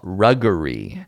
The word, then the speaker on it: Ruggeri: By Dom Ruggery. Last name pronounced like RuPaul, but instead of Paul, it's Jerry frickin' Seinfeld. Ruggery? Dom Ruggery? Oh, Ruggery. Ruggery. Wow, that's a tough one. – Mm. – R-U-G-G-E-R-I.